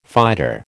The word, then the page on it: fighter: American English